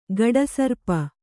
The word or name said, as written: ಘಟಸರ್ಪ